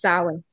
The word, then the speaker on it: Samhain: In Scottish Gaelic, it's usually pronounced Samhain. So it's two syllables. It's Saun or Saun or Saun.